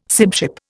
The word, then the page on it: sibship: Indian